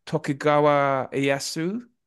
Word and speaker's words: Tokugawa Ieyasu: But I think he's based on Tokugawa Ieyasu.